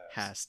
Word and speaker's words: Haas: You're mispronouncing Haas. It's Haas.